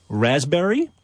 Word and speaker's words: raspberry: K-N-E-E-L. Neil. – Raspberry answer. – Silent letters. – Yes, silent letters. Yeah. Raspberry is the silent S. – P. – Or P. Sorry. – Silent P, right?